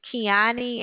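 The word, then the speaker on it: Keoni: But my name is Kiani, and they'll just transfer you over to me.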